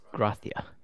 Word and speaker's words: Gracia: I actually know how to... It's Gracia. – Ooh. – T-H.